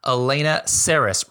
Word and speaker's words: Saris: Anyways, I've got an amazing guest today, Elena Saris, rhymes with Paris, here with us.